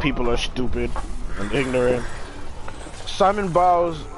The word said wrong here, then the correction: Simone Biles: Oh, I'm sorry. I did not mean to mispronounce a name like that. I said Simon too. Simone Biles. – Simone.